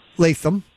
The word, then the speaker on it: Lapham: So tell us a little bit about Lapham Farm Village, which is pronounced Latham. Or Latham.